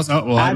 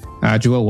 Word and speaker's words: Adjoa: Did I say that correctly? – Almost. – Oh, well, Adjoa, let's get it right. – Anyane-Yeboa. You just have to Adjoa, say it more perfect.